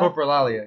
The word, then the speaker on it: coprolalia: which is coprobilia. I believe that's how it's pronounced. – Coprolalia? – Coprolalia. Yeah, that's it.